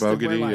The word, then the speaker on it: Abogidi: Abogidi was. Yep. – By the way, people who don't know that, like, that came out on Twitter that maybe that's actually how his name is pronounced. – Abogidi, yeah.